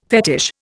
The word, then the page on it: fetish: Indian